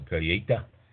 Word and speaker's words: colheita: A colheita, and it's spelled C-O-L-H-E-I-T-A, pronounced colheita.